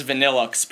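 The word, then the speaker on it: Vanilluxe: I know it's Vanillux, but I don't want to pronounce it that way.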